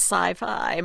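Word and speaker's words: Syfy: Which I think we're supposed to pronounce sci-fi.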